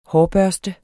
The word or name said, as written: hårbørste